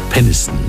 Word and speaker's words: Peniston: Schöner Sommerhit aus dem Jahr 1991 hier bei uns in Deutschland von Cici Peniston mit Finally. Muss man schnell aussprechen den Namen Peniston, sonst klingt es doof, ja? Sonst heißt sie ja Peniston.